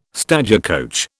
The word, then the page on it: stagecoach: British English